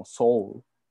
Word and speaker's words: Seúl: capital es Seúl, ¿verdad? Pero no sabía que en inglés se dice como Soul.